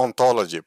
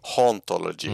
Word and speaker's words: hauntology: Eh, Han har lånat ett begrepp där i alla fall som, som Derrida kallar för ont, eh, ontology. På franska så är H1 eh, stumt så att man uttalar det precis som ontologi. – Mm. Eh, men det, står, det, det, det stavas alltså H-A-U-N-T-O-L-O-G-Y, ontology.